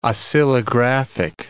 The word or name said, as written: oscillographic